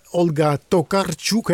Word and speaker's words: Tokarczuk: De rusteloze boek van Olga Tokarczuk. Hè? Zo spreek je het uit, neem ik aan. – Ja, klopt.